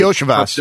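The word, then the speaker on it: Iosivas: Can't pronounce his last name, but it's spelled Losivas. Is it like Yosivas? – Oh, Cincinnati. – It's Yosivas.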